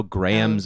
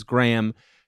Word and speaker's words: Graham: There are no Grahams in America. I say my name's Graham. 10 out of 10 times they go, Gerg? or they go, Gram, like the unit of weight.